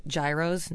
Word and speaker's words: gyro: Don't forget, G's are Y's in Greek. We don't have a hard G sound. So, like, of course, when people say, oh, they love their gyros. No, it's gyro.